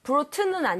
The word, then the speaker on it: brought: B-R-O-U-G-H-T. – Bring, brought, brought. 대신에 얘는 발음할 때 brought라고 하지 마시고요. 이마를 조금만 더 벌려주세요. – Brought. – 이런 느낌으로.